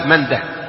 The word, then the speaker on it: منده: قال وقال ابن منده، وكان ابو داود ابن منده، منده دائما بالهاء، منده وقفا ووصلا، اسم فارسي، منده، منده وسيده، ابن سيده ايضا بالهاء وقفا ووصلا، وماجه، هذه الثلاثه كلها بالهاء، ماجه ومنده وسيده، ما يقال ماجه منده لا خطأ، لأنها أسماء فارسية تنطق بالهاء وقفا ووصلا.